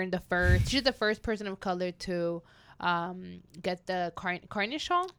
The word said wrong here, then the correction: Carnegie: I still can't pronounce that word. – Carnegie, baby. – Carnegie. – Carnegie. – Carnegie, girl.